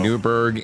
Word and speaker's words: Newburgh: Beautiful Newburgh, Indiana. – All right, sir. Newburgh, Indiana. Is there an H in that? Silent H? – There is.